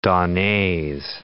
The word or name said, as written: donnees